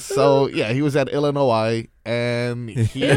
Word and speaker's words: Illinois: I was just about to say, when you said Illinois, it's Illinois.